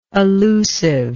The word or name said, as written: allusiveness